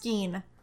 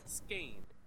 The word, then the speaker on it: skein: And it says skein. Skein. – Mm. – Like vein. – Okay. – Skein rhymes with vein.